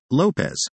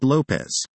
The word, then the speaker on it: Lopes: His name is spelled L-O-P-E-S, sang the lead lyrical soundtrack to that tranquil memory. He pronounces it, Lopez, not Lopez.